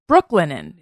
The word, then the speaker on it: Brooklinen: Brooklinen.